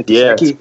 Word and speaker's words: Gesicki: I love the Gesicki kid from Penn State. Is that the correct pronunciation? The Gesicki? – Yeah. – Yep.